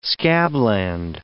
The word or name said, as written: scabland